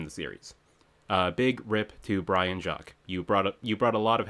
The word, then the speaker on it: Brian Jacques: It is Brian Jacques, right? – Yes.